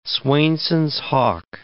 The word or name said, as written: Swainson's hawk